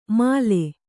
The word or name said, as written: ಮಲೆ